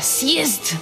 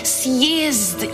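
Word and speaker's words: sjezd (съезд): Tako je, sjezd, ki povrhu vsega začne s cirilično črko C, ki se izgovori kot S, sjezd, ja.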